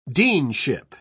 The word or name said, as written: deanship